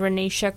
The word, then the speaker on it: Ranisha: If you can say my name Ranisha correctly, amazing.